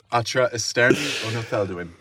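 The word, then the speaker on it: Atra esterni ono thelduin: Atra esterni onotheldwin, but roll your R's.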